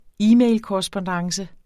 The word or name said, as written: e-mailkorrespondance